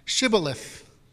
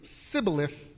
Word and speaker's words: Shibboleth: And then he says, well, then say Shibboleth. And if they say Sibboleth, then they pronounce it wrong.